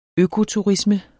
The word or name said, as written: økoturisme